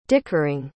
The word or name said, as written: dickering